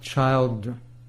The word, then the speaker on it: Childre: Doc Childra. It's spelled C-H-I-L-D-R-E.